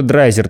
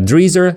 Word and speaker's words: Драйзер: А, дело в том, что драйзер – традиционное произношение, но а, и были разные варианты и в метриках, и в документах, и вообще в устном обиходе, в том числе варианты произношения фамилии. Кто-то называет его драйзером. В некоторых штатах США до сих пор говорят дризер, то есть скорее вот в таком ключе.